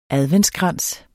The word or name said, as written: adventskrans